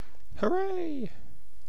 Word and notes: hooray: British English

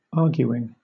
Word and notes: arguing: British English

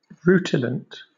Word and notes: rutilant: British English

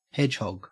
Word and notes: hedgehog: Australian English

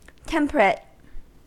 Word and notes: temperate: American English

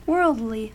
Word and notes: worldly: American English

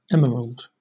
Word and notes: emerald: British English

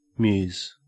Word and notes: muse: Australian English

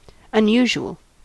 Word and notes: unusual: American English

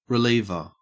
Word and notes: reliever: Australian English

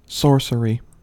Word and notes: sorcery: American English

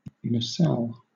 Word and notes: nacelle: British English

